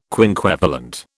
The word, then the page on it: quinquevalent: British English